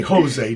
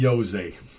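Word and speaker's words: José: But not in Spain, it's an H. You say Jose, not Jose.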